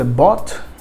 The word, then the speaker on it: bought: Vou destacar aqui também o by, que o passado é bought, que apesar dessa pronúncia, dessa escrita ser meio complicada, com G, H, T, você vai pronunciar como se fosse B-O-T, bought, o passado de by.